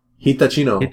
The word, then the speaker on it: Hitachino: And I never know how to say this brewery. – Hitachino? – Hitachino.